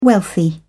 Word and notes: wealthy: British English

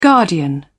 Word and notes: guardian: British English

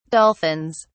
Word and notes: dolphins: British English

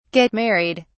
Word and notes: get married: British English

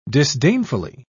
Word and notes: disdainfully: British English

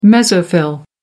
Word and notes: mesophyll: American English

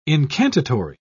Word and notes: incantatory: British English

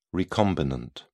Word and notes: recombinant: British English

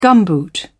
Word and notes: gumboot: British English

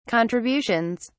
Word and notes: contributions: British English

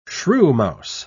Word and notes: shrewmouse: British English

